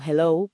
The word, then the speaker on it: hello: Esa H aspirada en hello, hello, por ejemplo, Uf, esa H sí nos cuesta un poco.